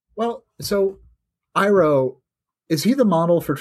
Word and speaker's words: Iroh: I always call him Iroh because Japanese pronunciation, excuse me, it's Iroh.